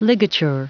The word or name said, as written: ligature